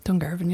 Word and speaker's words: Dungarvan: Dungarvan, yeah.